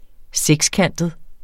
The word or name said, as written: sekskantet